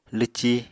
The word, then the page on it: lwci: Apatani